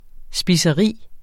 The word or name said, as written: spiseri